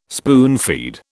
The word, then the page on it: spoonfeed: British English